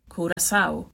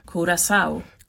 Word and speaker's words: Curaçao: Och så här ska det tydligen vara. – Curaçao. – Vi tar det en gång till. – Curaçao. – Curaçao. Så ska man uttala det här landet,